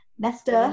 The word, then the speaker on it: Nesta: I always want to say Nessa. I don't pronounce my T's. Nesta.